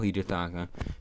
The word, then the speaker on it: Hidetaka: Hidetaka, jawel, dat is wel Hidetaka.